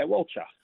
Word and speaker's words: Walcha: Walcha. Is that how you pronounce it? Walcha? – Yeah, Walcha.